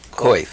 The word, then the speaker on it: Coif: Coif. – Coif.